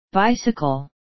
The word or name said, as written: bicycle